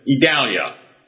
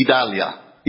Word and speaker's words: Idalia: And to me, it looks like a Hispanic name, and I would pronounce it Idalia. What would you do? – You wouldn't say an I at all. It would be Idalia. Idalia. – So I got the vowel sounds put in the wrong accent on the wrong syllable.